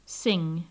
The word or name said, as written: sing